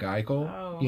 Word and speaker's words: Eichel: Somebody, you know, floating out there, his name rhymes with Michael, you know, Jack Eichel, you know, I'm thinking that, you know, but you move him.